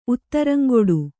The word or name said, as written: ಉತ್ತರಂಗೆಡು